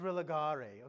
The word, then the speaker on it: religare: The word is religare. It sounds like Harry Potter. It's pronounced religare.